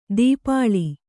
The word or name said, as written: ದೀಪಾಳಿ